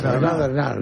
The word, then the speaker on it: Bernhardt: Tienes que pronunciar a la francesa, Bernhardt. – Sara Bernhardt, ¿no? – Hay que pronunciar a la francesa.